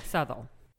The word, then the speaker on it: Southall: And then she ends up falling into this job teaching a writing class at a gurdwara in this Punjabi enclave called, I think, Southall. I don't know how to pronounce that correctly. – Yeah, Southall.